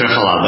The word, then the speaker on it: Triphala: We say Triphala, but yeah, either way.